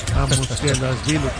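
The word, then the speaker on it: Mamukelashvili: Yo creo que tú alguna vez estuviste en la República de Georgia porque pronuncias muy bien eso de Mamu Kelashvili, como si de verdad.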